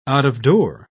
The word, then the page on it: out-of-door: British English